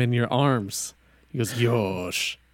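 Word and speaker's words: yosh (よし): The word yoshi is like a word that it shows affirmation. Like, let's do this. I've got this. And it's pronounced yosh.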